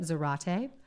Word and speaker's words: Zarate: And after this attack on the community, one of them, a woman named Nomi Zarate, it's spelled like karate with a Z, so that's why I'm pronouncing it Zarate, started, so she's one of his moles.